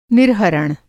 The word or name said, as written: निर्हरण